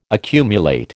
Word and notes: accumulate: American English

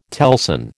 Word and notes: telson: American English

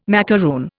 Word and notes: macaroon: Indian

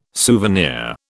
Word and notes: souvenir: British English

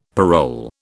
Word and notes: parole: British English

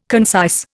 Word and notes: concise: Indian

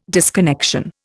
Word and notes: disconnection: Indian

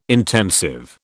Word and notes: intensive: British English

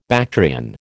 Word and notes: Bactrian: American English

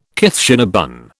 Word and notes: Kitchener bun: British English

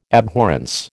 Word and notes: abhorrence: American English

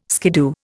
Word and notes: skiddoo: Indian

